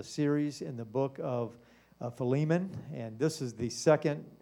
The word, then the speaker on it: Philemon: Now, one thing I do want to say that Brother Dobbins, right before church, he has an app on his phone, and they do pronounce it Philemon. So there are three different ways to pronounce but I'm going to call it Philemon tonight, so that's going to be the pronunciation.